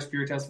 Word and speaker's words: Spirtas: It is Spirtas. It looks like Spirtas, but it's Spirtas. It's good to be here. – Connor Spirtas, what's up, man?